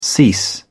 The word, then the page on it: cease: British English